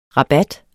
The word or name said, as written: rabat